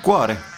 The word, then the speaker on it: cuore: Cuore. Che poi a Benevento, dove sono nato io, si dice cuore, accento sulla U invece che sulla O.